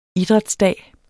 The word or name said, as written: idrætsdag